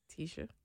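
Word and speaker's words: Tesha: Tisha. Yeah, Tisha. If I say it right, because it's T-E-S-H-A.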